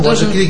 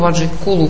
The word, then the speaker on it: Vajrakila: You should pronounce Om Kili Kili, Om Vajrakila Kili Soha, not Kulu Kulu. – Ты должен ее произносить как Om Vajrakila Kili Soha, а не как Om Vajrakula Kulu Soha.